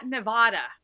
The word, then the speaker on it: Nevada: And I'm impressed that you said Nevada right. That is a mouthful for many people in the U.S. They often pronounce the name wrong. So kudos to you. I like you already. – How did they pronounce it? – Nevada. – Ah. – Nevada. With the A vowel.